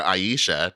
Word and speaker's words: Ayesha: It's I'm gonna just call it Asha because I'm playing it in Japanese and that's how they pronounce it, even though it's spelled A-Y-E-S-H-A.